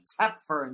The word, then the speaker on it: Toepfer: Betty Tepfer, and yes, that is how you pronounce her last name. T-O-E-P-F-E-R, not Topfer or Tepfer, Tepfer.